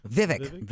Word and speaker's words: Vivek: Vivek.